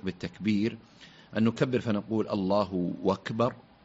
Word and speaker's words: الله أكبر: لا شك أن الصيغة هي الله أكبر، الله الهاء مضمومة لفظ الجلالة، وأكبر الهمزة مقطوعة، همزة قطع، همزة قطع، الله أكبر، هذا هو الأصل في الصياغة.